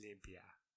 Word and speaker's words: limpia: Limpia. – How do you say it? Limpia. – Limpia. – Yeah, that's good enough.